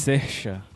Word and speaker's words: Saoirse: Como é o nome de novo? Sesha. Sesha. É muito difícil pronunciar, porque é S-A-O-I-R-S-E.